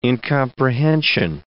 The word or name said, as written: incomprehension